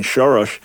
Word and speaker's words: Soros: So for me as a conservative, I look at all the funding that George Soros has done, or his Hungarian pronunciation, Soros.